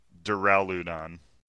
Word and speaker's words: Duraludon: Dioladon? – Dioladon. – Yeah, that sounds good. – Duraludon.